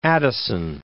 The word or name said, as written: Addison